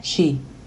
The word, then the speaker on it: Qi: Sus movimientos están diseñados para equilibrar la energía del cuerpo, o el Qi, que se pronuncia Qi.